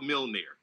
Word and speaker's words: Mjolnir: It's called Mjolnir. – What? Nah, dog. There's a J in the name. What are you? – There is, but it's a silent J. It's like Nordic.